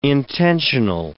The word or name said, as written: intentional